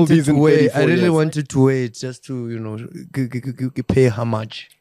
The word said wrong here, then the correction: homage: Pay homage.